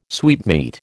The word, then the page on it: sweetmeat: American English